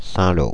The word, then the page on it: Saint-Lô: [sɛ̃.lo]; France (Île-de-France)